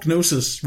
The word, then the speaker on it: gnosis: And the G is silent. – Gnosis, yep. Whatever.